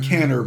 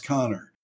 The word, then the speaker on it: Kanner: It looks like Canner, but it's pronounced Connor.